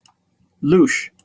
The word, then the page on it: louche: British English